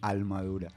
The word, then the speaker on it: Almadura: Almadura, un juego de palabras como con la palabra armadura, como sí, en la pronunciación puertorriqueña, ¿no? Almadura. – Con la L.